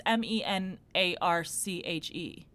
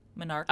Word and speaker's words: Menarche: But it's M E N A R C H E, Menarca.